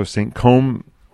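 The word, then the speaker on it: Saint-Cosme: Chateau Saint-Côme, which is spelled C-O-S-M-E.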